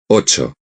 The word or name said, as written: ocho